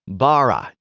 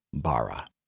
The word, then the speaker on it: bara: Now, the Hebrew word translated as created is the word bara. Now, I pretty much poorly pronounced that word because I can't roll my R's, and that word is supposed to have a rolling R-type sound,